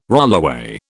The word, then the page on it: rollaway: British English